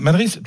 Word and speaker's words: Madrid: Pourquoi le S d'ailleurs ah, Madrid, tout simplement, c'est l'accent castillan. Quand un D est la dernière lettre d'un mot, ça se prononce Z.